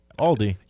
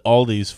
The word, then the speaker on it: Aldi: You said it properly. Aldi. – Yeah, it's not possessive. – Mm-hmm. – People do that all the time. – I called it Aldi's for years just because that's what my parents called it growing up.